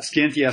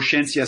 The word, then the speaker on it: Scientia: And it's called Scientia Salon or Scientia Salon. Actually, the two pronunciations are both good as far as we know from Latin.